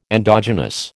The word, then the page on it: endogenous: American English